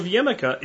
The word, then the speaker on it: jicama: Uh, we're going to use a magical vegetable called jicama, and it's spelled like jicama, J-I-C-A-M-A, jicama, and this is a root vegetable native to Central and South America, grows as a tuber, and it tastes mostly like apple.